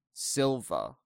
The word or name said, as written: Silva